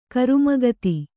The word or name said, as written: ಕರುಮಗತಿ